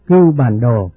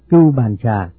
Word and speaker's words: Cưu Bàn Đồ: Đúng ra những chữ này phải đọc là Cưu Bàn Đồ, nhưng hiện nay những người tụng chú đều đọc là Cưu Bàn Trà. Có lẽ Cưu Bàn Đồ cũng biết mình bị đổi tên rồi, do vậy quý vị có đọc là Cưu Bàn Trà thì cũng được linh ứng như nhau, cho nên tên gọi thì không có gì là nhất định cả.